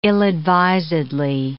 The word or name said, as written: ill-advisedly